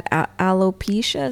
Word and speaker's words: alopecia: Like, I didn't know that his wife had alopecia.